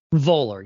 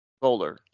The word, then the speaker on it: Voller: Voller. Yeah, I think it's Voller.